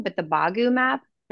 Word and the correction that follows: Bagua: Bagu map? Don't ask me. – Yeah, the Bagua map. Mm-hmm. – Okay, Bagua. Great. That's how you say it.